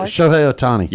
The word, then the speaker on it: Shohei Ohtani: Ohtani Shohei or Sho- Shohei Shohei Ohtani.